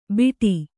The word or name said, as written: ಬೀಡಿ